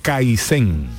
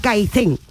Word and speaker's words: Kaizen: Eh, esto lo he pronunciado bien, ¿eh? – Sí, Esto lo pronunciado... Kaizen. – Kaizen. Ojo, la Z es queriendo porque está bien pronunciado.